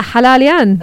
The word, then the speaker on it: Hala Alyan: Yeah, Hala Alyan. – I love the name. It's the way you say it, I would say it more like, yeah. Hala Alyan, which is kind of how it's spelled.